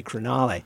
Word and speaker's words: Crugnale: And it's funny because Siri pronounces it Crugnale. So okay. If I need to call someone in my family, I have to say Crugnale, which is actually, it's an Italian name, actually.